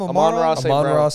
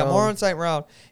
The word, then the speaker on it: Amon-Ra St. Brown: Amon-Ra St. Brown. – Yeah, Amon-Ra St. Brown.